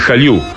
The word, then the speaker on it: Jallu: En realidad, este es el segundo trabajo de Louis Jaliu. Se escribe J-A-L-L-U.